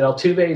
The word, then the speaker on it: Altuve: Bill, the second baseman, is it Altuve? Is that how you pronounce? – Yeah.